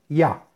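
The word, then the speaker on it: Ya (יה): Trae un nombre sagrado para meditar y son las letras Yud, Hey, y se pronuncia Ya.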